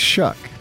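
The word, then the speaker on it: Shough: It looks like Shuff or... – I know, and I screwed up. – Shuck. – It's Shuck.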